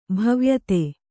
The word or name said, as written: ಭವ್ಯತೆ